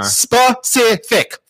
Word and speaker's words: specific: I know how to say it, because there's one thing that pisses me off when people are like, Pacific. Man, you need to be more Pacific. Yeah. Like, more oceanic? I don't get it. Yeah, I hate that, and I hate... – Specific. Three syllables.